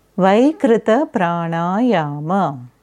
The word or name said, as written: Vaikrita Pranayama